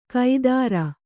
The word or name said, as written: ಕೈದಾರ